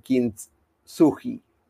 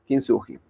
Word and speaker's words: Kintsugi: Algunas ideas para crear confianza antes de ir al cierre de lo que es eh, esta historia del Kintsugi. Se pronuncia así, si no me equivoco, porque lo, pasé para, el traductor japonés y, se pronunciaba de esta manera, Kinsuji.